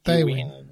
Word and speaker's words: Tywin: Sí, Tywin.